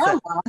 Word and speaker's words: Corella: So, I couldn't pronounce Corella, it. – That's okay. Yeah, I know. In Italian, you have to roll those R's.